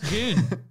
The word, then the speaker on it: Dune: It says it's pronounced Dune. D-U-N-E, he said.